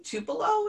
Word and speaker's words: Tupelo: Um, so the the movie was shot um, in Tupelo.